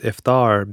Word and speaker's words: iftar: Same with iftar, the meal at the time of breaking fast. A very common way to pronounce it is iftar in the Western world. We chose to go with iftar because it was more authentic to the original pronunciation.